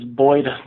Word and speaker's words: Boyd: So my name is spelled B-O-Y-D. My Twitter handle is Boyd Norwood, B-O-Y-D-N-O-R-W-O-O-D.